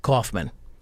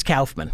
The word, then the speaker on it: Kaufman: Well, so Kaufman is how it ought to be pronounced in English. You know, A-U is aw. – Aw. – But in German... it's Kauf.